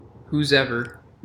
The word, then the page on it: whosever: American English